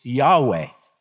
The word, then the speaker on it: Yahweh: We don't really know definitively how to pronounce that name. The best we can come up with is YHWH is Yahweh.